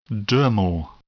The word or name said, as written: dermal